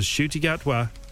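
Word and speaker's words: Ncuti Gatwa: That is the name you need to remember, because Shruti Gatwa is, and I had to go and check this pronunciation because I didn't watch Sex Education.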